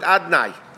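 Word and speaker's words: Adonai: V'nikra ani ba'alav dalid, but we don't pronounce it that way. We pronounce it alav dalid nun yud adnai.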